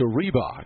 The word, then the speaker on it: Riebock: Now 1-2 to Reebok, who does not spell it like the old shoe company, but pronounces it that way. He spells it R-I-E-B-O-C-K.